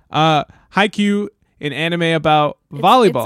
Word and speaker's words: Haikyuu: That's how you say it, Haikyuu? – Haikyuu. – Haikyuu. – Yeah, Haikyuu. And I think there are two exclamation points.